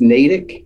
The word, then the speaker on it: Natick: I guess it's pronounced, the town is Natick. – Natick, Massachusetts.